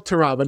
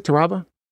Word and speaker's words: Taraba: So today we're thrilled to welcome Gisselle Taraba. Did I say that correctly? Taraba? – Yes, Taraba.